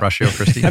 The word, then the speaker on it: Ratio Christi: It's like pistachio, ratio. – Yeah. – Ratio Christi.